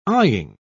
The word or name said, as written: eying